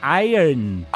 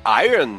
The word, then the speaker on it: Iron: Que não se fala Iron, se fala Iron. – Iron? – Não se fala Iron Maiden, se fala Iron Maiden.